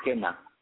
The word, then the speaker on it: Qena: Yeah, that instrument was played with Kena. It's spelled Q-E-N-A.